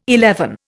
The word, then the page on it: eleven: Indian